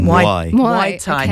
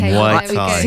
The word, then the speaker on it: Muay Thai: Muay Thai. – Muay Thai.